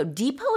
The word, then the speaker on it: depot: Depot is spelled D-E-P-O-T. We don't pronounce the T at the end, so it's pronounced depot. And it's a local or regional facility that's used for short-term storage, sorting, and dispatching goods before the final delivery. – Exactly. I think you can also pronounce it depot, but you never say pot.